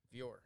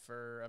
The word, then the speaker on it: Furrer: It's Fuhr. I've been called everything. Fuhr. but so it's Fuhr, F-U-R-R-E-R, but used to be spelled F-U-H-R-E-R. So German, and my grandfather fought in World War II.